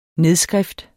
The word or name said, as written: nedskrift